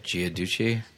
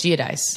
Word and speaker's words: Giudice: Well, Teresa, how do you say it? Giaducci? – I think it's Giudice.